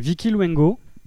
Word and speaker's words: Vicky Luengo: Allez, avec Vicky Luengo, hein, c'est ça, je l'ai bien prononcé? – Oui, très bien.